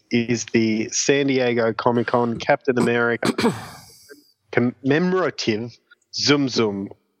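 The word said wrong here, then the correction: Tsum Tsum: Sum Sums? – Sum Sum, yeah.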